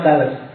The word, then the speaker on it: Thales: Kalau kamu tidak percaya bacanya memang tales.